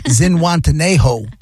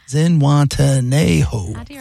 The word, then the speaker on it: Zihuatanejo: Zihuatanejo. Zihuatanejo. 5 syllables. – Zay. It's Zihuatanejo.